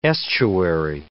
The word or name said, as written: estuary